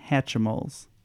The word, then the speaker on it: Hatchimals: We were kind of... fighting over what the pronunciation of this is, and we settled on Hatchimals. – That's the exact pronunciation. Oh, Hatchimals.